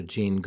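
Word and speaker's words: Gene: And all of my social medias are Ira Jean Goodman something or other. So it's Ira Jean Goodman Realtor on Facebook and Instagram and Ira_Realtor on TikTok.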